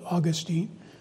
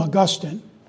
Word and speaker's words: Augustine: I'm trying to learn to say his name the way most people say it, scholars say it, don't know why, because it's spelled Augustine. But they call him Augustine.